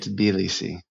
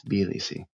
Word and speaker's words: Tbilisi: My understanding is you say it Tbilisi. – Tbilisi. – Yeah. – I've been pronouncing it wrong. – Emphasize that first I, Tbilisi.